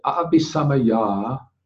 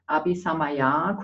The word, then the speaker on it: Abhisamaya: But Abhisamaya, short A. – Abhisamaya, kurzes A.